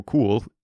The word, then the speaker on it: Kuhel: Joe Cool, which is just the coolest name, Joe Cool. It's spelled K-U-H-E-L. And I thought it's probably like Kuhel or something, you know, not as fun as cool. But no, it is apparently cool.